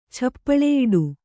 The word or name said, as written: ಛಪ್ಪಳೆಯಿಡು